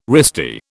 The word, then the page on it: wristy: British English